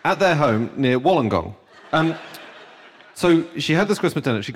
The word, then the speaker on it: Wollongong: In Australia, at their home near Wollongong, and she Wollongong? – Is that how it's pronounced? Is it like that? – From the reaction, I'm not sure that's how you do pronounce it. – Wollongong.